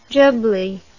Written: jubbly

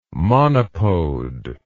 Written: monopode

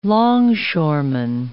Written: longshoreman

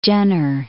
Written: Jenner

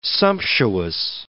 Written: sumptuous

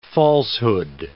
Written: falsehood

